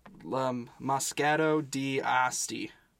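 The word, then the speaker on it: Moscato d'Asti: Moscato di Asti. – Great pronunciation.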